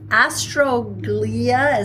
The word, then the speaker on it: astroglia: So the astroglia, is that how you say it? – Yes.